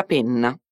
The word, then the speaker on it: penna: Fai attenzione alla pronuncia della doppia N in penna, perché pena con una N sola esiste anche in italiano. Attenzione a non confonderle.